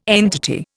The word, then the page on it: entity: Indian